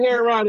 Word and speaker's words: Heron: It's It's just like the bird, heron.